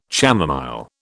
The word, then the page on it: chamomile: British English